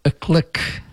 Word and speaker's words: iklik: The word iklu, or iklik sometimes, I-K-L-I-K, but these are Turkish andaradais, which are pronounced as like in garage, the first syllable in garage.